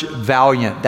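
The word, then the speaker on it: Vaillant: A psychologist from Harvard I met years ago named George Valiant. That's how he pronounces his last name.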